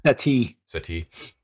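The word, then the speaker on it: settee: Am I saying that right? Is it seti? Settees? – Seti.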